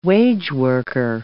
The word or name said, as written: wageworker